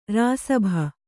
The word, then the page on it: ಋಷಭ: řṣabha